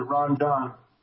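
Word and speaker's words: Rondon: Oh, by the way, the name is, I pronounce it Ron Don. – Oh. – Like it's spelled, yeah. It's confusing because people think I'm like, you know, Spanish descent, but I'm not. – Okay.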